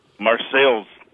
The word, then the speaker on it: Marseilles: How do you pronounce the name of your town? – Marseille's. – Marseilles, all right.